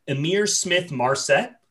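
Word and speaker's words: Ihmir Smith-Marsette: I'm going to butcher this name, but Amir Smith Marset, I believe. – Yeah, that's good. Yeah.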